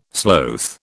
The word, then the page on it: sloth: British English